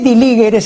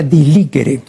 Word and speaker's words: diligere: Ahora, la castellanización de diligere, este diligere se pronuncia así, ¿no?, como si fuera la G de guerra, ¿no?, pero es la pronunciación, porque en latín no existe el sonido fuerte de la G, ¿no es cierto? Entonces suena así, pero se escribe diligere, ¿no? Claro, pero se pronuncia diligere.